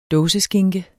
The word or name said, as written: dåseskinke